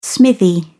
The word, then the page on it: smithy: British English